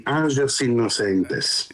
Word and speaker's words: Angelos Innocentes: Ma l'associazione si chiama, mi perdoni se sbaglio la pronuncia, Angelos Innocentes dei Frati Minimi di San Francesco di Paola, è così? – Sì, Angelos Innocentes.